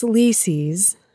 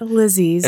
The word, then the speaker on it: Lisi: Lisi's story, which it's Lisi's. Figured that out. It's not Lizzie's. – It rhymes with Cece.